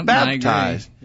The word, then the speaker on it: baptized: I think if I'm hearing, I'm not good at hearing those accents, Anthony, but if I'm hearing it right, I say it with the accent on the first syllable. – Yeah, baptized.